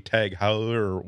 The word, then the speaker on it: Heuer: You can get the I'm so not the market for a Tag Heuer watch that I have no idea how to pronounce Hoyer. Tag Heuer. – Tag Heuer. – Heuer. Yes.